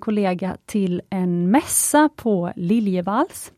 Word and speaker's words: Liljevalchs: nu har de tagit reda på hur man ska uttala det och då, man ska uttala det liljevalchs.